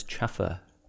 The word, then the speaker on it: chauffeur: Always pronounced it as chaffer. – That's just weird. – Chaffer. – I disagree with that. I think chauffeur is not spelt that weirdly that you can't figure out how to say it.